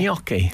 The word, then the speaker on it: gnocchi: Yeah, Gnocchi.